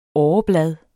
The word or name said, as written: åreblad